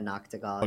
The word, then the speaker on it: Noctigol: Um, I guess looking at some of the the features on Johto Legends, I see uh, names like Michaela Noct- Noctigol. Is that how you pronounce her name? Sorry for butchering yeah, it. Yeah, Michaela Noctigol.